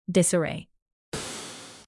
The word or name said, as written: disarray